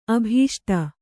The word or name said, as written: ಅಭೀಷ್ಟ